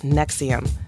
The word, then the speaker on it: NXIVM: It's spelled NXIVM, but it's pronounced Nexium, like the acid reflux medicine.